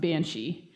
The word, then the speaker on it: Bean Sidhe: So just to kind of explain what a Banshee is, well, first off, the traditional spelling is actually B-E-A-N-S-I-D-H-E. Bean, it's basically bean, and then Sidhe is what it looks like, but it's pronounced Banshee.